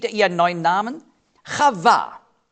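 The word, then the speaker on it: Chava (חוה): Auf Hebräisch spricht man aus Chava. Er nannte sie Chava. Auf Deutsch sagen wir Eva.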